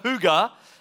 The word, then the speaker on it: hygge: It's spelled H-Y-G-G-E. So, and when you move to the Northern Hemisphere, that becomes hygge, hygge, hygge, if you're Danish.